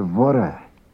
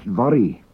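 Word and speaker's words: Voirrey: And actually, they think that it's pronounced Vori. And Vora means, hey, Mary, or oh, Mary, or even off Mary. But it is never, ever pronounced Vori.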